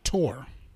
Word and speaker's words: Thor: And Thor is actually pronounced Tor.